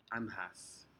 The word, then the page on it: anhas: Aneityum